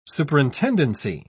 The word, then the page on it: superintendency: British English